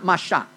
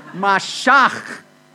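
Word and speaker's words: Mashach (משח): No, not Mashach. Mashach. You have to spit at somebody to say this right.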